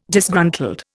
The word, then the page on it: Disgruntled: Indian